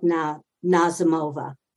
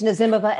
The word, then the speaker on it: Nazimova: We have to learn from these women like Nazimova. Thank you for not correcting me. I mispronounced her name. – That's okay. It's Nazimova, and everybody does.